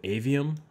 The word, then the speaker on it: Aveum: All right, so the next, yeah, the next game that they showed was Immortals of Aveum.